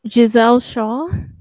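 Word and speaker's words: Gisele Shaw: We have Trinity and Deonna Purrazzo against G Saw and Savannah Evans. – Giselle Shaw. – I knew it began with a G.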